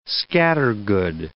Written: scattergood

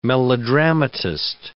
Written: melodramatist

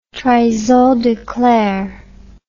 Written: trahison des clercs

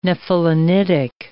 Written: nephelinitic

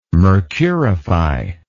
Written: mercurify

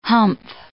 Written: humph

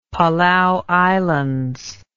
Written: Palau Islands

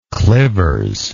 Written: clivers

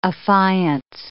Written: affiance

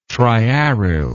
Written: triaryl

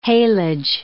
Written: haylage

